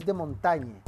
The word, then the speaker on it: de Montaigne: Por otro lado está eh, Maurice de Montaigne... De Montaigne... así se pronuncia, en francés, va a plantear la filosofía como reflexión.